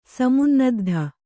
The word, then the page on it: ಸಮುನ್ನದ್ಧ: samunnaddha